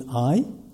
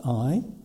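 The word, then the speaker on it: Ai: My research partner is named I, spelled A-I, like I, pronunciation, and meaning love in Japanese.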